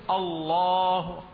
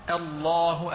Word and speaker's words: الله: في بعض الناس مثلا إذا لم يغلظ إذا لم يرقق الهمزة سوف يقول الله الله ففخم الهمزة معها، هذا خطأ. الصواب الله أعلم، الله الله. لاحظوا هنا عندما نطقت لفظ الجلالة بهذه الكيفية رققت الهمزة والهاء، وتغليظ اللام هنا يعني في وبضدها تتميز الأشياء.